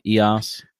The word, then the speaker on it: EOS: So let's talk about like what's the future look like for KRC as a part of uh, EOS. Am I saying that right? EOS? – Uh, EOS. EOS. – EOS. EOS. Yeah. Um, like like the sun god.